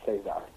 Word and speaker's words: Cesar: I was gonna say most Filipinos I know with the name that looks like Caesar is pronounced Cesar.